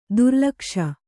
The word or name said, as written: ದುರ್ಲಕ್ಷ